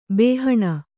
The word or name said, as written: ಬಾಹಣ